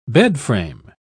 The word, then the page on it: bedframe: British English